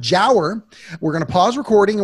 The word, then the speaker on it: giaour: The word is jower. It's spelled G-I-A-O-U-R, and it's pronounced Jower. – Jower, I don't know it. – G-I-A-O-U-R. A lot of vowels there. – G-I-A-O-U.